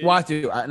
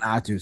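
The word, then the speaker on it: Uatu: Watu. Not Atu. Sorry. I said it wrong.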